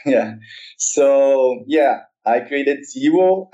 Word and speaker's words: Zewo: So I believe the correct pronunciation would be something like Zwo. It's zero in a modified French. So it would be something like Zwo.